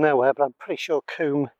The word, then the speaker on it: combe: So we've got sort of Babbacombe, Maidencombe, spelt C-O-M-B-E, but pronounced Coombe.